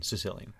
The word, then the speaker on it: caecilian: C-A-E-C-I-L-I-A-N. Sicilian. – Sicilian. Pronounced like Sicilian.